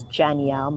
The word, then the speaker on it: Janielle: I'm majority of the time I'm on Instagram, so that is Janielle Mckoy.